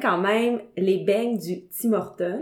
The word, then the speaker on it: Tim Hortons: Un Tim Hortons, c'est un Tim Hortons. Nous, là, le trait d'union, là, il est après le Tim. – OK. – C'est comme ça qu'on prononce ça. Tim Hortons.